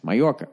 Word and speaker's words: Mallorca: Now that makes sense, Mallorca, right? Because in Latino, Hispanic there, whatever the fuck you're supposed to say, the J is a Y, right? Like it's Jose.